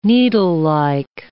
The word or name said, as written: needlelike